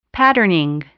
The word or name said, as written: patterning